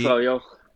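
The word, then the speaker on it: Jungfraujoch: Tell me how to pronounce this. It's Jungfrau, like J-U-N-G. – Jungfrau-Joch. – Okay. – Jungfrau-Joch. Yeah.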